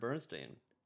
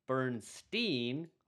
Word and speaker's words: Bernstein: So also, Elmer pronounced his name Bernstein, ooh, and Leonard was Bernstein.